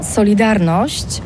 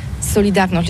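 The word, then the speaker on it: Solidarność: Alors solidarité, en français on dit Solidarnosc, mais ici à Gdansk on ne prononce pas Solidarnosc. – Solidarnosc.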